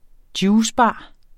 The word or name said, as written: juicebar